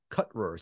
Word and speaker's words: Cutrer: The name of the co-author that I completely butchered was Caleb Cutrer, C-U-T-R-E-R, so you can follow him and follow Roxanne about her research.